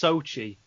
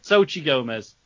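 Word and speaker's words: Xóchitl: Apparently it's pronounced Sochi, so that's good. – Ah, okay. – Sochi Gomez. – So you put a bit too much flair on it, if anything.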